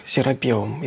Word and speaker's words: серапеум: Я просто для уважаемых зрителей напомню, во-первых, чтобы зрители не ждали, что я сейчас буду рассказывать опять про серапеум, все-таки я настаиваю, что ударение ставится в этом слове так, серапеум.